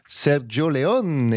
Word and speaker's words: Sergio Leone: Et que ça se prononce Sergio Leone. – Exactement, ça se prononce exactement comme ça.